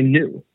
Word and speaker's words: New: Yeah, my last name is actually New. – New. – Yep. Very, exactly how it's spelled. – Very N-E-W.